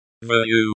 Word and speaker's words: Верю: Верю, the, you, at the end sounds like the, у, in, moon.